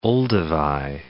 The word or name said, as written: Olduvai Gorge